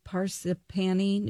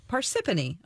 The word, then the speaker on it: Parsippany: Her office is actually in New Jersey in, I don't know how to say this, Parsippany, New Jersey. – Parsippany.